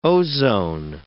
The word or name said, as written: ozone